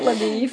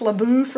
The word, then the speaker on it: LaBoeuf: And by the way, you know, in the book, his name is spelled like L-A-B-O-E-U-F or something like that. – Yeah, it sounds French to me. – It sounds French. And it looks like it would be pronounced LaBeouf or something. Mm-hmm. But I did read one commentary, and they said they think that they mispronounced his name as LaBeef, kind of as a poke of, to poke fun at him because he was from Texas.